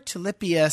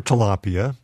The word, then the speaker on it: tilapia: And 4 tilapia steaks, basically tilapia fillets. – Or tilapia, if you want to pronounce it correctly.